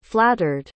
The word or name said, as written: flattered